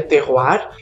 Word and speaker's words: terroir: É uma palavra de origem francesa, se escreve terroir, se pronuncia terroir, e terroir significa o conjunto de 3 fatores: a mão do homem, o solo e o clima.